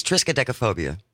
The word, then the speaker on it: triskaidekaphobia: It's triskaidekaphobia.